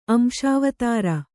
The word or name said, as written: ಅಂಶಾವತಾರ